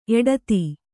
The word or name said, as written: ಎಡತಿ